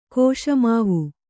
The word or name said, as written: ಕೋಶಮಾವು